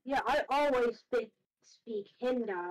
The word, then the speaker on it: Hindi: And then I made a funny little one that was Hindi or die. – No, it's pronounced Hindi though.